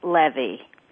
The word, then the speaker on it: Leve: I just want to say my last name is L-E-V-E because most people would never know that. – No, they would. And I was pronouncing it Levy, realized it was Levy, right? – Yeah, it is pronounced Levy because, like everything else in my life, it's as complicated as possible.